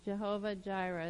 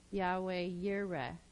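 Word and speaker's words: Yahweh Yireh (יהוה יראה): And he says that he reveals his name there as Jehovah Jireh is how we pronounce it in English, but it would be Yahweh Yireh, and that means the Lord who sees.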